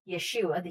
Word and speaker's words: Yeshua: And he, Yeshua, the Hebrew pronunciation of Jesus, is the Lord.